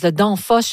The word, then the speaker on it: Danfoss: Alors, on parle d'une compagnie de logiciels danoise, Danfoss, je ne sais pas si je le prononce comme il faut. – Oui, mais tout à fait.